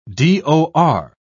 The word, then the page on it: dor: British English